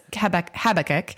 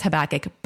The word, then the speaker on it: Habakkuk: So that's just the Brit way of saying it. So that's a correct way to say it. Canada and Britain says Habakkuk. America says Habakkuk. Both are correct.